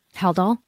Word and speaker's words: Haldol: I hear you saying Haldol, but to me I'm hearing hell doll.